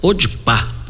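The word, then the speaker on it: Podpah: Me parece que a pronúncia é podpar, podpar, uma coisa assim.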